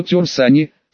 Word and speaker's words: Тер Сани: Так что свое тер сани, с ударением на второй слог, Тин не озвучивала вовсе, были у нее сомнения, что это имя принадлежит ей по праву.